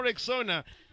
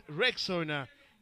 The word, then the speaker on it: Rexona: Guys, I'm sure you've seen that Shield is now Rexona, but it appears that some people don't know how to pronounce this new name. A friend even called it Rexona. Guys, it's Rexona, like Rex Owner, Rexona.